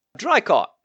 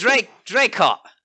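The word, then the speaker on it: Draycott: Drycott. – Is that D-R-Y? – It is now, because I said it wrong. – Not Drycott. – It's Drycott. Pardon me. – D-R-A? – D-R-A-Y.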